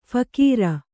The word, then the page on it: ಫಿಕೀರು: phikīru